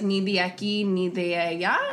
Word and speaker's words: ni de aquí, ni de allá: I never felt, I know that there's a saying like, ni de aquí, ni de allá.